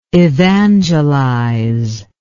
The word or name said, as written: evangelizer